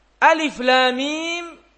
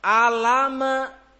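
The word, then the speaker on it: alif, lam, mim (الم): Karena itu bukan bacanya alama, alama, bukan. Itu bukan huruf tersusun jadi sebuah kata. Tetapi itu dibaca alif, lam, mim.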